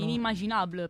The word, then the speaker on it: Inimmaginable: Inimmaginable, penso si pronunci. – Inimmaginable. Perfetto, sì, sì, esatto, Mamma.